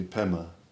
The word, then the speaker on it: Pema: It's the Tibetans can't pronounce Padma. That's so they do Pema.